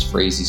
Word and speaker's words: Frazee: No, I am crazy, which is spelled with the Y, but my last name is Frazee, spelled with two E's.